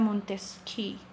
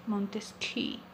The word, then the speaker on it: Montesquieu: Soy yo, su host, y hoy vamos a hablar sobre Montesquieu, dicho vulgarmente. Pronunciación correcta, Montesquieu, Montesquieu.